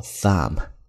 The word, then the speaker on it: thumb: 其次我们看大拇指叫做 thumb，咬舌头，并且呢最后一个字母 B 是不发音的。